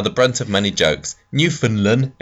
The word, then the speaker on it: Newfoundland: A quick pronunciation point. Newfoundland is pronounced Newfoundland. They are the Welsh of Canada in that no one can understand their accent.